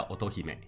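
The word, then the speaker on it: 音姫: 上面写“ 音姬” 两个字，嗯、音乐的音，姬就是姬路城的姬啊，那个念起来叫 otohime。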